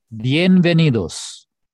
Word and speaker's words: bienvenidos: Bienvenidos.